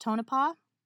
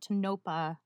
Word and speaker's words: Tonopah: All right, a final note, the name of the town in today's episode is pronounced Tonopah, and while I managed not to embarrass myself by saying it incorrectly in front of any residents, I did call it Tonopah in some of the live footage, so beware, be gentle.